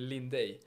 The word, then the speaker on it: Lindey: Och hur uttalar det, är antar att man tänker på efternamnet då, det är väl Lindey. – Lindey. – Ja, det brukar vara lite knepigt med uttalet, det har varit i alla år, men ja, det är det rätta i alla fall.